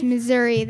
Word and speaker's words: Missouri: The state I can't pronounce because I always call it Missouri. – Missouri? – It's Missouri. Thank you. I cannot pronounce it.